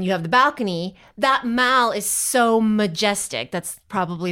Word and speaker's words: Mall: It's spelled M-A-L-L, but the way you pronounce it, if you're like English people, is the Mall.